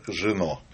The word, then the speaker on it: Жено: И дебютанты программы «Цена Победы» актриса Мария Вдовина и режиссер Георг Жено. По-французски ударение делаем. – Да, второй все срок. Правильно.